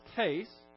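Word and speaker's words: heis (ἧς): It's just, in the English, just transliterated, it would be H-E-S. But it's pronounced heis.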